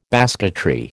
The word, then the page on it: basketry: American English